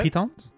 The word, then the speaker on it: piton: How do you say it? – It's piton. – It's piton. – Is it piton? Is it piton? – Yeah, the beer is called piton.